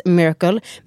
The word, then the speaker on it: Myrkl: Det uttalas Miracle, men det stavas Myrkel, alltså M-Y-R SKL.